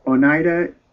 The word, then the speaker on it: Oneida: Oneida, is that how you pronounce it? – Oneida Indian tribe, and traveled the Hudson River in the early 1600s, seeking members of other Oneida villages.